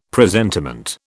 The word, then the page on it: presentiment: British English